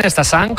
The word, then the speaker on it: Stassin: Las dos cesiones, tanto de Stassin, del belga, como de Félix Correa. – A pronunciarlo bien, Stassin, como nos han apuntado, y Félix Correa, como dice José, con muy buena pronunciación portuguesa.